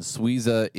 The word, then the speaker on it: Sweeza: It's a little bit easier for us to pronounce here with this new spelling of Suiza, S-W-E-E-Z-A.